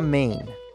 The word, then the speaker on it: Maine: Se llama Maine es el único estado de, una sola sílaba.